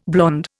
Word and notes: blond: Indian

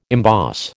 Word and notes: emboss: American English

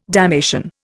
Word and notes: damnation: Indian

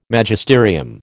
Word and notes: Magisterium: American English

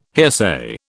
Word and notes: hearsay: British English

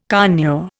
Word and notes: conure: Indian

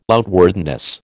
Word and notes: outwardness: American English

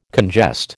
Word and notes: congest: American English

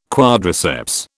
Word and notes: quadriceps: British English

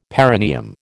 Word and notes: perineum: American English

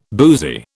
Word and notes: boozy: British English

